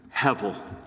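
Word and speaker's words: hevel (הבל): Do you know that the name Abel, that Abel's name in Hebrew is the word hevel? Again, spelled H-E-B-E-L, the B pronounced as a V.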